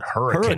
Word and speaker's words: Hurricane: Uh, it's spelled Hurricane, but they call it Hurricane.